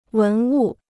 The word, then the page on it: 文物: wén wù